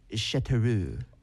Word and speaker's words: Châteauroux: I might butcher the pronunciation of this, but I'm going to try. Châteauroux. – Oh, that's good.